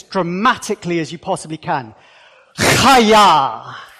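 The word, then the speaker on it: Chaya (חַיָּה): And then the second word, let me explain how to pronounce it. Chaya. So that, the dot under it, it's a very guttural sound. You're wanting to spit on the back of the head of the person in front of you.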